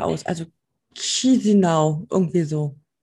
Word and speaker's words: Chișinău: Die Hauptstadt heißt Chișinău. Ich glaube, da spricht man so aus. – Die Allgemeinheit sagt, glaube ich, Chișinău. – Echt? Ach, da ist auch kein R drin. Chișinău. Für mich heißt das ja Chișinău.